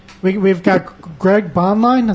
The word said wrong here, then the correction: Bainline: Is that how you pronounce your last name? Bonline? – However the hell you want to pronounce it. It's Bainline, but... – Bainline.